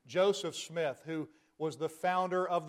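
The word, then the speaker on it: Smyth: Some pronounce him as Jonathan Smythe, but Jonathan Smith, who was the one who founded the Baptist Church.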